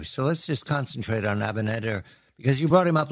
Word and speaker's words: Abinader: Another piece of good news, and it's rare, Dominican President Luis Abinader, do I say that correctly, Abinader? – Yes.